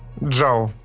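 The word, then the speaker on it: Чжао: 26-й э, фильм Marvel от режиссера Лой Джан, по-моему, фамилия произносится. Джао. Джао, да.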